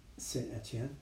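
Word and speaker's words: Saint-Étienne: Saint Etienne.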